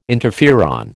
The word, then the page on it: interferon: American English